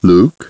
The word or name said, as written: Luke